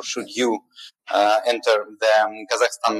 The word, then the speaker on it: Kazakhstan: And I know that in Italy, everybody told Kazakhstan. Yeah, so that the right pronunciation is Kazakhstan to make it everything crystal clear.